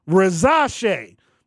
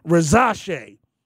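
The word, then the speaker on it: Risacher: Yeah, so he says Rizache. And you know, so the commissioner has people, you know, tell him exactly how to say it. So that's probably the correct pronunciation, Rizache.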